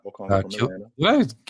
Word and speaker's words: Okongwu: James Wiseman, LaMelo Ball, Patrick Williams, Isaac Okoro, Onyeka Okongwu. I hope I said that right. – Yep, Okongwu.